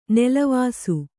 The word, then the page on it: ನೆಲವಾಸು: nelavāsu